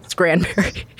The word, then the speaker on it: Scranberry: Like cranberry with an S in the front of it. – Oh. – Scranberry.